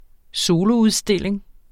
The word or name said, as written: soloudstilling